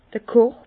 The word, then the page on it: d'r Korb: Bas Rhin; Herrlisheim